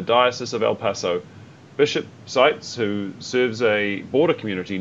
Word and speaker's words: Seitz: Denouncing the demonization of migrants, hateful rhetoric, the militarization of the border, and a system that divides family, Bishop Mark Seitz, how do you pronounce that one? – Seitz, probably. – Seitz, yeah, that'd be right, the one I didn't use, of El Paso in Texas, called on Catholics to heed the Church's teaching to welcome the migrant.